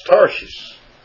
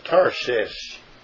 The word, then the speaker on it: Tarshish: And it's pronounced Tarshish, kind of like Tarsus. But it's Tarshish.